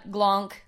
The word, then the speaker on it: Glanc: Go check out Glonk. – Yeah, Josh Glance, but also it's spelt Glonk, G-L-A-N-C.